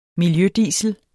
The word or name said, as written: miljødiesel